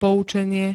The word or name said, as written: poucenie